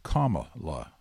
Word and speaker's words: Kamala: He went back and forth between Kamala and Kamala within the body of the speech. And he knows better. He's known her for some time, and he knows the way it's supposed to be pronounced. But even he was slipping up a little bit. So comma la, comma la, comma la. Okay? Think of the punctuation mark. That's the way it goes.